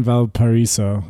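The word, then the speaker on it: Valparaíso: So when you were in Valparaiso, am I saying that right? – Mm-hmm.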